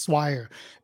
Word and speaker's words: Swire: my name is not hard to pronounce after you know how to pronounce it. It's just Swire.